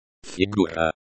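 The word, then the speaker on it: Figura (фигура): Figura. Colon, the, u, sound is like the, u, in, moon.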